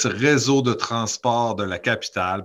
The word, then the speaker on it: Réseau de Transport de la Capitale: So it's Réseau de Transport de la Capitale, but it is the Quebec Transit Authority for the Quebec City.